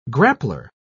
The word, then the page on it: grappler: British English